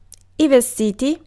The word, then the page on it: vestiti: /veˈstiti/; /ˈvEstiti/